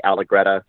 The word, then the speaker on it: Allegretta: Allegretta.